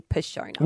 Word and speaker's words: Passiona: So this is like literally just a PSA of next time you go and get a Passiona, it's actually pronounced a Passiona.